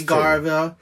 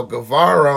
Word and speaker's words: Guevara: The opening match was one of my favorites, Sammy Garvey. you know. – Guevara.